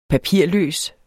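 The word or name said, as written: papirløs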